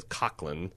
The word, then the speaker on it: Coughlin: Now, there is a throw line from Coughlin, which I've found out is the correct way to pronounce his name is Coughlin. – Coughlin? – It's an Irish brogue thing.